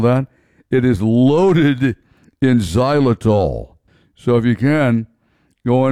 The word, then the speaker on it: xylitol: Xylitol. X-Y-L-I-T-O-L. Pronounced xylitol.